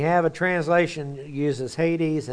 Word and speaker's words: Hades: Hades is the way it's actually pronounced.